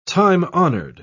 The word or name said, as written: time-honored